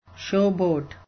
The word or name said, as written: showboat